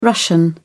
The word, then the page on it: russian: British English